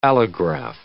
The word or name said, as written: allograph